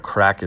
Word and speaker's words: craic: And as they say, the crack is 90. Crack, if you don't know that word, look it up. It's spelled C-R-A-I-C.